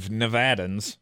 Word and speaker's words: Nevada: Hey, I used to say it that way, and then I was reprimanded by native Nevadans. – Really? – Yeah, and that's how they say it, apparently.